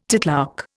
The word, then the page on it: titlark: Indian